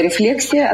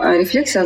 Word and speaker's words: рефлексия: Я называю рефлексия от слова reflection, ну, поэтому ударение. Кто-то называет рефлексия, кто-то говорит, фу, я вообще не хочу называть это слово.